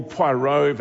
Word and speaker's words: Poirot: Hercule Poirot, that's the way you pronounce it, and Miss Marple, very popular and have so much rerunning going.